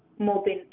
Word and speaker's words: Mobin: M-A-H-N-O-O-R. – Mahmoor. Okay, and last name? – Mobin. M-O-B-I-N.